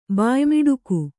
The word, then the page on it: ಬಾಯ್ಮಿಡುಕು: kannada